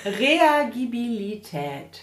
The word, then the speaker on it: Reagibilität: mit, mit Re der Betonung ist sie damit so. Rea Reagibilität. Reagibilität.